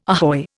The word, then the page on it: ahoy: Indian